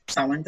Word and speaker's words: Samhain: So typically pagans start the Wheel of the Year at Samhain, which is spelled S-A-M-H-A-I-N, pronounced the Celtic way, Samhain.